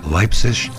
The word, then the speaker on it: Leipzig: Also a pure all-out rocker from Melbourne, Australia's Leipzig Lab, buzzing in on Unloved. I pronounce it the German way.